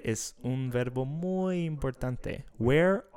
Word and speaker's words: where: Where are you from? – Tú dijiste where, pero la pronunciación es where.